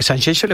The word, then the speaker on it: Sanxenxo: Lo pronuncias. Sanxenxo, ¿eh? – Es como Shakira.